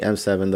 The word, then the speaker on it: Eme7: My contact info again is EME7. You can just call me M7, though it's easier to pronounce. You don't have to say MS7, but whatever works.